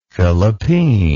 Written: fillipeen

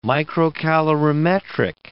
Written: microcalorimetric

